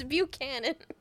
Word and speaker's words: Buchanan: I'm gonna butcher this. Oh, I remember how you say it. I was gonna say Buchanan. It's Buchanan.